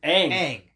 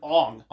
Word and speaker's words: Aang: It's Aang. They called him Ong. – Ong. They were trying to overcompensate. This is Ong.